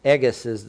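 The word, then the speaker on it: Ingus (ἐγγύς): Ingus is the proper way to pronounce it, and that means it's drawn closer.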